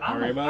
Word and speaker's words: Haribo: The third one is... Harborough? Is that how you pronounce it? – Haribo? I thought it was Habanero when I wasn't paying attention, so who the fuck cares? – Haribo? Gummy bears.